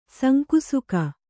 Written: ಸಂಕುಸುಕ

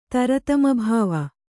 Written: ತರತಮಭಾವ

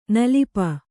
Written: ನಿಲಿಪು

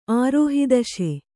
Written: ಆರೋಹಿದಶೆ

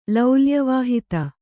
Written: ಲೌಲ್ಯವಾಹಿತ

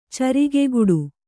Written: ಚರಿಗೆಗುಡು

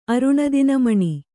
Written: ಅರುಣದಿನಮಣಿ

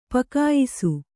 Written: ಪಕಾಯಿಸು

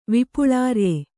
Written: ವಿಪುಳಾರ್ಯೆ

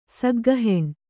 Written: ಸಗ್ಗಹೆಣ್

